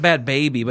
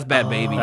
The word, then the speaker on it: Bhad Bhabie: I think it's pronounced Bad Baby, but it's spelled... – B-H-A-D-B-H-A-B-I-E. – Yeah, that's bad. That's Bad Baby.